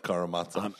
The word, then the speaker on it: Karamazov: Karamazov.